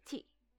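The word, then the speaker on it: chi (四): Por ejemplo, el número 4 se pronuncia chi, exactamente igual que la palabra muerte.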